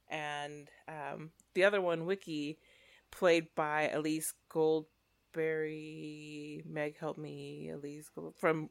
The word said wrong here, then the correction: Goldsberry: Renee Elise Goldsberry, yeah.